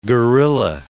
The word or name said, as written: gorilla